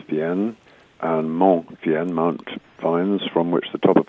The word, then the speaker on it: Fiennes: So my original French cousins, although it's spelt the same way, over here it's pronounced Fiennes,